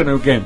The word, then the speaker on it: Neuquén: Claro, porque se pronuncia Neuquén. – Pero claro, Neuquén, sí, es verdad, Neuquén.